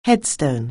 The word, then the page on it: headstone: anglais